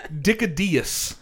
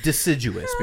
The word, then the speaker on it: deciduous: This motherfucker said Dickadeus because if you look at the spelling of the word. That's how it's pronounced.